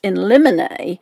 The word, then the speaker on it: in limine: So I just looked that word up, and it's pronounced in limine,